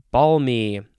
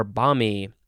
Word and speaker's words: balmy: Next, we have balmy or balmy. You can pronounce the L or you cannot pronounce the L. That is totally up to you. You choose how you want to live your life. But it is spelled B-A-L-M-Y.